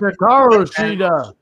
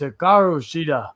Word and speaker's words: Hikaru Shida: It's the women's world title match between the champ Hikaru Shida versus Nyla Rose, being accompanied by Vicky Guerrero. – Correct. And it's Hikaru Shida.